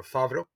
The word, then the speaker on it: Favro: One, I'm founder and CEO of Favro, or Favro. You can pronounce it whatever way you want.